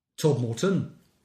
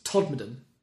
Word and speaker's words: Todmorden: It's not Todd Morton, it's Todmorden.